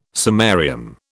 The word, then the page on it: samarium: British English